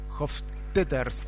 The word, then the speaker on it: Hofstetter: Sí, bastante bien pronunciado. Es más complicado el apellido de pronunciar que de escribir que de pronunciar. – Sí, es Hofstetter simplemente, así que...